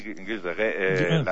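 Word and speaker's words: Gilles de Rais: Juan Antonio, ¿en qué momento pasa por tu mente la idea de escribir sobre, yo no sé si lo pronuncio bien de la introducción, sobre Gilles de Reyes? – Sí, Gilles de Reyes, la verdad es que ocurrió, uh-huh, sí, hace ya 5 años.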